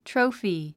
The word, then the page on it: trophy: tróufi; トロフィ